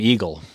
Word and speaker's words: Egil: I mean, my name is Eagle Knutson, but it's spelled E-G-I-L. It's a Norwegian name.